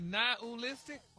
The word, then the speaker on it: nihilistic: Nihilistic? – Wow. – Stop putting the ooh in there.